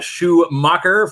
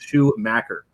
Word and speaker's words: Schumacher: It came up in council, and the city has asked to rename, and we're asked to rename 4th Avenue and Schumacher, or Schumacher for the out of towners. Oh, that city always gets butchered. The town, it's Schumacher. – Can you spell Schumacher? – In a spelling bee, S-C-H. U-M-A-C-H-E-R.